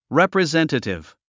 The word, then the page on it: representative: rèprizéntətiv; レプリゼンティティブ